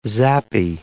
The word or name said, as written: zappy